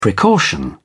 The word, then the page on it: precaution: British English